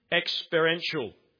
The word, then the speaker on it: experiential: Now, this knowledge is both intellectual and experiential. Is that how you say it? Experiential? Yeah. An experience.